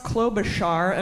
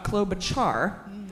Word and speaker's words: Klobuchar: We've been told it's pronounced Klobuchar and not Klobuchar. Okay, fine. Doesn't have a hard CH, so thanks to Stephanie Machaud for that.